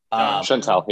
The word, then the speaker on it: Chantel: I also like the deep cut of Lourdes Chatel. Is that, I think I'm saying that right? – Chantel, yeah.